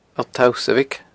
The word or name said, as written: Allt Tausabhaig